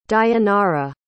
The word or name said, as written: dayanara